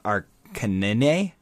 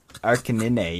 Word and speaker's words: Arcanine: So yeah, I actually think it's pronounced Arcanine. Yeah. – Oh yeah, yeah, Arcanine. – Yeah, it's Arcanine. Yeah, that's actually how it's pronounced.